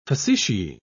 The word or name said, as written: facetiae